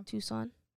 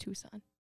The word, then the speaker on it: Tucson: Tucson. You really pronounce that C in there. Tucson. It's just Tucson.